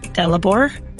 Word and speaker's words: Dalibor: Dalibor. D-A-L-I-B-O-R. – Dalibor? – Dalibor, yeah.